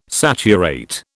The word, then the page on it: saturate: British English